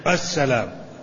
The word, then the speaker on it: السلام: السلام أل الداخلة الشمسية، هنا أل شمسية، لأن في أل قمرية وهي التي تظهر، والشمسية التي تكتب ولا تقرأ ولا تنطق.